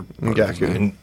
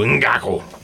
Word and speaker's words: Ngakoue: I can't pronounce Yannick's other Ngakoue. – Ngakoue. Yeah. You got to put emphasis, Yannick. Though. You got to go Ngakoue.